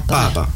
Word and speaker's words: papa: Papa. – Papa. L'accent tonique, c'est sur l'avant-dernière.